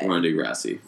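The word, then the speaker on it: Ronda Rousey: So, endoskeleton of the week goes to Rhonda Rosé. – Rhonda Rousey.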